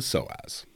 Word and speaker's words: psoas: So there's a muscle called the psoas. – Yes. – P-S-O-A-S. Very, very important muscle in the body. – Silent P. – It is a silent P.